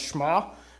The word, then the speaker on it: Shema: But to the Jewish person, this is called the Shema. I was corrected, and, and it's actually pronounced Shema, but that sounds silly.